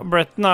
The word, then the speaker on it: Breton: Eh, det er eh, så er det Bretton, eller Breton. Hvordan uttaler man dette her? Bretton, ja.